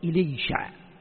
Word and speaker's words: إليشع: وليسع، وفي قراءة الليسع، الليسع في قراءة وقراءة وليسع، وفي العبرانية إليشع، إليشع، إليشع. طيب، هكذا ينطق في العبرانية.